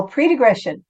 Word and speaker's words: pre-digression: Is it a pretergression? – Oh, pre-digression.